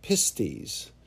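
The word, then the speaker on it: pistis (πίστις): But the term that's used for believe, the basic or most common Greek word for belief is actually pistis.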